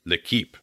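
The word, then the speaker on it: L'Équipe: Or Tony Parker, who has said that he, to the French sports daily L'Equipe, did I say that right, French speaker? – L'Equipe, thank you.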